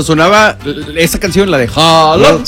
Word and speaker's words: Haaland: Que me dicen que no se pronuncia Haaland, es Holland. – Holland. – Nosotros le decimos Haaland porque viene a. Sí. Pero es Holland.